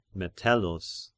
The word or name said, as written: Metellus